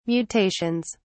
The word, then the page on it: mutations: British English